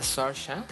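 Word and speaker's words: Saoirse: Seguramente se va a pronunciar de un modo completamente Ah, sí, bueno, si, se pronuncia Sarsha.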